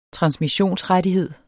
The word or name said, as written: transmissionsrettighed